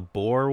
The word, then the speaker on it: Boer: The Bear War? The Boer War? – Boer. – Okay.